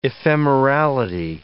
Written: ephemerality

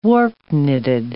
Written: warp-knitted

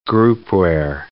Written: groupware

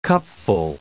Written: cupful

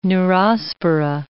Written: neurospora

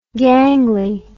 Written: gangli

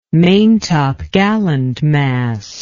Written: maintopgallant mast

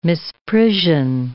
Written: misprision

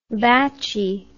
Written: batchy

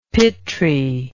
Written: pitri

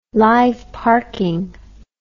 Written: live parking

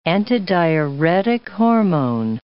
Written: antidiuretic hormone

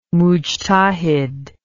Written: mujtahid

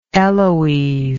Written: eloise

